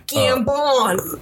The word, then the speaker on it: Gambon: Gambon.